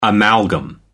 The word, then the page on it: amalgam: American English